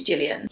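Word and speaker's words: Cillian: I think it's Gillian. – Yeah, I think so. Well, yeah, it is, yeah. – It's spelt with a C, but I feel like it's an Irish name.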